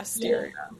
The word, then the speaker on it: Asteria: Is it Asteria Clothing? Am I saying that right? – Yeah, Asteria. Yeah.